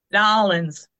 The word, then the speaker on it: New Orleans: New Orleans. Like, think of it N-A-W, nah. – Okay. – I just have 12 beers and try to say New Orleans. – Yeah, New Orleans.